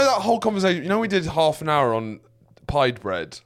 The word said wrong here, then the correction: pita: Yeah, it was pita bread. I said pied bread about... – Can't be that. Pita.